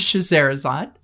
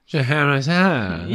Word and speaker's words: Scheherazade: The second is Scheherazade, which I think I'm saying that correctly. – Sounds right to me.